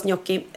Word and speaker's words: gnocchi: Alltså, NJ, alltså när man uttalar NJ. – Ja, gnocchi. Mm. Gnocchi. Fast alla säger gnocchi. Ja. Men det är uttalas gnocchi.